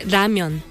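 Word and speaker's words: ramyeon (라면): Bueno, puede decir ramen si es japonés, y el coreano se dice ramyeon. – Ramion.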